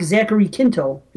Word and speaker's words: Zachary Quinto: So anyway, the guy that plays Spock, Zachary Quinto, is that how you pronounce his name? – Yeah.